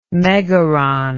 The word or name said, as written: megaron